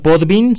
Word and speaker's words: Podbean: La página se llama Podbin, se escribe Podbean, eh, diagonal, halcones financieros.